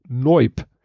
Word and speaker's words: noyb: Und dann hatte, habe ich äh, None of Your Business, habe ich NIOP gesagt. Und dann hat er nochmal gesagt, ja, die Abkürzung ist aber N-O-Y-B und das ist halt halt NOIP.